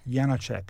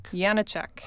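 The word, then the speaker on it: Janáček: Um, and Leos, am I saying this right? Janacek? – Janacek. – Janacek.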